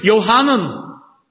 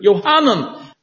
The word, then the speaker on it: Yohanan: It's from the Hebrew Yohanan. That's how it's pronounced in Hebrew. Yohanan.